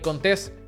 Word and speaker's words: Contés: Así que mi nombre es Miguel Contés con acento en la E y nos vemos en la próxima.